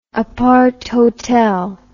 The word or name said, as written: aparthotel